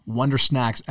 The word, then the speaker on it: Wondersnaxx: Make sure, once again, you type in the search bar, Wonder Snacks as it's spelled, that's Wonder, and then S-N-A-X-X.